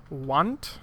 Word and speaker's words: Wandt: They officially founded as a brewery in 2012 with their brewmaster, Dennis Wandt. That starts with a K. Silent K, W-A-N-D-T, with much inspiration from his wife, Tammy.